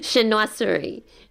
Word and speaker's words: Chinoiserie: Chinoiserie.